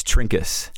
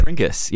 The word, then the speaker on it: Trinkus: It's Trinkus. – Trinkus? Really? – Trinkus, yeah. If you were from Germany, it'd be Trinkaus.